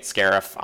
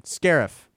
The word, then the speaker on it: Scarif: And I, by the way, they've been pronouncing it Scarif on the DLC for Battlefront. – Okay, then. Scarif.